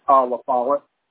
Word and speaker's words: LaFollette: Is it Lafayette? Fayette? Is that how you pronounce it? – LaFollette. – LaFollette, okay. There's no why. Why was I saying Lafayette? Like if there's a why.